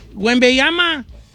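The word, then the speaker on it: Wembanyama: Don Cheto, a ver, dígame, ¿cómo se dice? – Wembayama. – Wembayama. – Wembayama. Como le dice todo el mundo.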